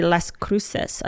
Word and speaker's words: Las Cruces: This is in New Mexico, by the way, Las Cruces. I don't know if I said that right. – Ah, yes.